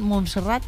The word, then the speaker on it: Montserrat: ¿Montserrat? – Sí, Montserrat.